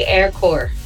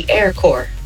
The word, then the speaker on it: Corps: Why don't we pronounce the P when you say air core? It's spelled C-O-R-P-S, and we say the air core.